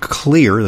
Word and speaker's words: Xlear: Spry Dental Products and Clear, that's spelled X-L-E-A-R.